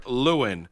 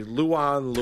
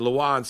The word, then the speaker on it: Lewan: You hear about my boy Taylor Lewan. – Lewan. – Right, whatever. Lewan. – I'm just telling you how you say his name.